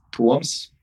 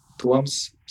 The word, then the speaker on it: Toms: But if you really want to be correct, then even my first name is a bit different. – Yeah. – It's Toms. – Toms? – Yeah. – Okay. – Toms Skujins.